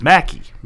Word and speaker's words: Mackey: My last name is usually the first name that people don't know how to uh, pronounce, but it's Mackey.